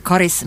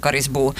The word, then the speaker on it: Karis: Ska Karis uttalas med kort eller långt A? Det är frågan. – Och Karisborna, de är tydliga. De säger Karis med kort A. Och för många är det väldigt viktigt för just den här identiteten som Karisbo. Men om man pratar med språkexperter så har de kanske en lite annan uppfattning. Institutet för de inhemska språken rekommenderar långt A, Karis, alltså i offentliga sammanhang.